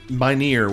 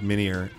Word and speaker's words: Minear: Like, poor Tim Minear, which I found out that is the actual proper pronunciation of his name. – Oh, really? – I always thought it was Minear.